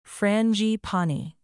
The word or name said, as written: frangipani